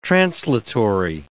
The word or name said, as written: translatory